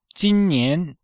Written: jin1nian2

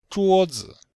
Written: zhuo1zi5

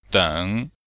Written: deng3

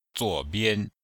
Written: zuo3bian5

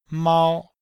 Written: mao1

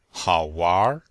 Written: hao3wanr2

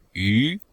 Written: yu2

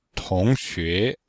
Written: tong2xue2

